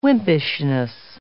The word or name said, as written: wimpishness